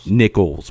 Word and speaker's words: Nichols: Nichols with, like, not like the... It's like the money, but it's spelled Nichols.